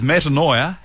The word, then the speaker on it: metanoia: Metanoia. – Metanoia? – I think. – So M-E-T-A-N-O-I-A? – Correct. – Okay. – Metanoia. – Yep. – Nailed it. Is metanoia having too many thoughts?